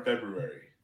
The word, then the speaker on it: February: What happened to the R in February? Like, no one pronounces that R. – I do. – February.